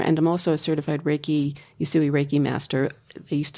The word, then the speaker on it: Reiki: And that brings me to another question, which I had, which is, when you're talking about this, as it's different from Reiki, how do you say that? – Reiki.